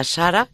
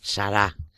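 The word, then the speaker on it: Sarah: Yo decía Sara y está muy mal dicho porque es Sara. Se escribe Cardenal Robert Sara. Sara. Es S-A-R-A-H y el libro Dios o Nada, del que ya hemos hablado.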